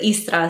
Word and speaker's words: Istra: In Croatia, we pronounce it Istra, so you drop the second I.